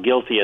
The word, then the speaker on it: GILTI: It's spelled G-I-L-T-I, but it's pronounced guilty as in caught you cheating.